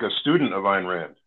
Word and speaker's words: Ayn Rand: Anne Ryan. How do you say her name again? I forgot. – Yeah. Oh, Ayn Rand.